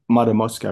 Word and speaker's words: Matamoska: Did I say Matamoska, right? – Yes, you said it right, yes.